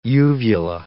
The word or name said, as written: uvula